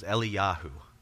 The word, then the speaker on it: Eliyahu: And so today in the interest of alleviating this confusion, when I talk about Elijah, I'm going to use his Hebrew pronunciation which is Eliyahu.